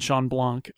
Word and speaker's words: Shawn Blanc: Um, and I you know people like Tom Merritt and Ben Thompson and and and Shawn Blanc